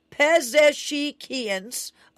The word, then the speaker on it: Pezeshkian: now has apparently blocked President Masoud Pezeshkian, let's pronounce this right, Pezeshkian's,